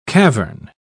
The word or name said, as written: cavern